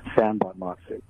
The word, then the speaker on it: Sanbonmatsu: It's pronounced San Bonmatsu, which is actually how it's spelled, S-A-N-B-O-N-M-A-T-S-U. It took me to third grade to learn how to spell that name, San Bonmatsu. – You know, it's funny because I think I was close on that, and I was going to say I didn't want to butcher it. So it's San Bonmatsu. The way it looks, basically.